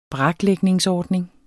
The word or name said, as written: braklægningsordning